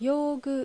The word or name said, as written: Yougu